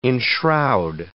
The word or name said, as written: enshroud